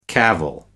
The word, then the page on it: cavil: American English